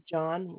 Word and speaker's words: Jon: First name is John, which is spelled J-O-N. And then the last name is Barba, which is spelled B-A-R-B-A.